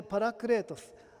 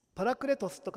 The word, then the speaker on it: パラクレートス: 助け主という言葉は、ギリシャ語でパラクレートス、わざわざ脚注にカタカナで書いています。パラクレトスと書いていますけれども、